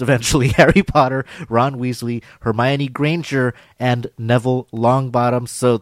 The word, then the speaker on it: Hermione: Is it like Hermione? – Hermione.